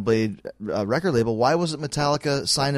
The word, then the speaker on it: Metallica: I remember the first time a guy showed me the Metallica record, my friend was with me, pronounced it Metlica.